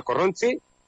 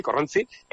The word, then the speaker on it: Korrontzi: Por cierto, Corronzi, no sé cómo pronunciarlo, es precisamente el primer disco, ¿no? – Efectivamente, sí, el grupo se llama Corronzi. – Corronzi. – Decimos así, Corronzi.